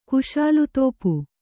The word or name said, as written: ಕುಶಾಲುತೋಪು